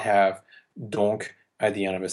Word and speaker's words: donc: The other example from Carmen we have is donc pour me tenir compagnie. This is the case where donc is at the beginning of the clause, there's a comma after it, it is pronounced.